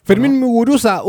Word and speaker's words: Fermín Muguruza: Oye, lo dije bien, Fermín Muguruza. – Fermín Muguruza, un grande de la música del rock de protesta ahí de Euskal Herria. – Perfecto, muy bien.